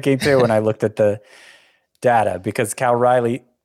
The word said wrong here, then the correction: Cal Raleigh: Cal Raleigh, did I say that right? Yeah, it's like the capital of North Carolina.